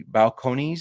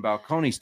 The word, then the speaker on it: Balcones: Balcones, is that what you said? – Yes, I think. Well, that's what Google told me for pronunciation. – All right. If it's wrong, it's Google's fault. We're drinking Balcones Texas rye, which is 100 proof rye, pot distilled straight rye.